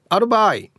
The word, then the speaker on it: あるばーい: A アルバイ、えー、マルバイのイントネーションですね B 中豊。